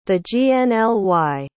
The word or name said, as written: thegnly